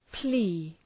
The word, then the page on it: plea: pli: